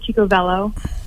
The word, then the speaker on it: Chico Velo: Am I pronouncing that right? Velo, Velo? – I think it's tomato, tomato. I pronounce it Chico Velo.